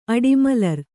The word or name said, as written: ಅಡಿಮಲರ್